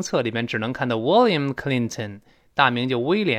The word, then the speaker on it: William: 其实呢，这个比尔的大名呢，我在新概念第三册也讲过，应该叫 William，就是威廉，叫 William。但是 William 这个发音不好发，特别是这 L，有些美国人也觉得它别扭。 William，William 这舌尖往前指的，所以呢，昵称呢干脆就 Will，W I L L。